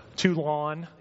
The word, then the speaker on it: Toulon: Toulon? Did I say it right? Toulon. Okay. The siege of Toulon.